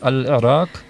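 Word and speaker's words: Al-Iraq (العراق): Gerade beim Namen Irak, das ist sehr schwierig, weil es dort 2 Buchstaben gibt, die für Deutsche fast unaussprechlich sind. Das ist einmal Ein, der Buchstabe Ein, und dann am Ende Qa. Und der Name Al-Iraq, das kriege ich auch selber nicht hin. Das ist Al-Iraq. Ja, das ist relativ schwierig auszusprechen. Und so wurden wir von ihm ziemlich gut geschult in der Aussprache.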